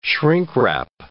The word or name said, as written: shrink-wrap